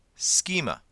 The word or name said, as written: schema